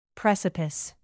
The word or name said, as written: precipice